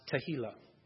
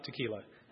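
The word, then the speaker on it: Tehillah (תהלה): Next word is H8416, and it is pronounced Tehillah, with a little more H. I can never do the Hebrew H. I can't do it. It's Tehillah. Sounds like tequila if I say it like that. It's not tequila.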